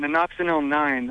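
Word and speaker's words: Nonoxynol-9: Yeah, I got just a little bit of a random question about Nanoxinil 9, the spermicide. That's how you say it, right? – Yep.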